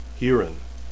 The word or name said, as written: hurin